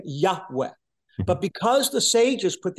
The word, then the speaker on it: Yahweh: And because language changed and the Y sound in Hebrew became changed to a J sound in English, Christians think that God's name is pronounced Jehovah.